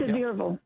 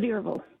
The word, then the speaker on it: Sevierville: Am I saying it right? – Sevierville. – Say that again? – Sevierville.